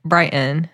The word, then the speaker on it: Brighton: The teacher we're about to hear from, Kristen Leathers at Brighton High School, or as it's pronounced here, Brighton.